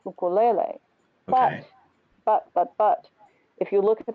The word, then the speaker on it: ukulele: So the Hawaiian syllabication is ukulele. – Ukulele. Okay. – Ukulele, because Hawaiian, you know, has beautiful syllables that repeat. Right. – And not diphthong vowels. – Uku lele. So lele is twice and u is twice. Ukulele.